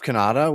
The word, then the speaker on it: Cannata: You can find me on socials at Jeff Cannata, which is spelled with two N's and one T.